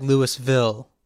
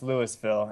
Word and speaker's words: Louisville: Wait, is it Louisville, Colorado, or is it actually like Louisville? – I asked, and it's pronounced Louisville, and I asked if it was Louisville, and I got laughed at.